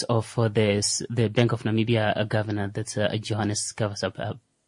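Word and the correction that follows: !Gawaxab: Am I pronouncing that? – Khab. – Gavakhab, yeah.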